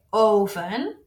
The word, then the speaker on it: oven: Same spelling, slightly different pronunciation. We say oven with a clear O.